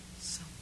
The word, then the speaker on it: Sampo: Sampo.